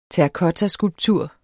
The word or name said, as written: terrakottaskulptur